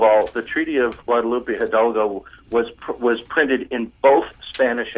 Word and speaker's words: Hidalgo: And I mispronounced it. It's Hidalgo. – Hidalgo. – Thank you, Rose. – Got to roll your R's.